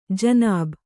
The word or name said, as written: ಜನಾಬ್